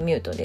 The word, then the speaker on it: muute: アルファベット小文字で MUUTE と書いてミュートです。